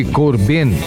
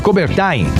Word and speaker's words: Coubertin: Em 1894, foi fundado o Comitê Olímpico Internacional em Paris por iniciativa do barão Pierre de Corbin. E em 1949, Coubertin, acho que deve ser assim a pronúncia.